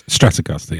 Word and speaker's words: Stratocaster: It's Stratocaster, yeah.